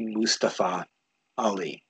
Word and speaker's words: Mustafa Ali: And I know we've been calling him Mustafa, and it's actually supposed to be Mustafa Ali. That's how he wants it pronounced.